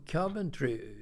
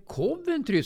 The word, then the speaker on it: Coventry: Men sen kommer ju Coventry. Bengt Grive sa ju alltid, det heter Coventry, sa han då, Bengt Grive. Det heter inte Coventry, kommer jag ihåg.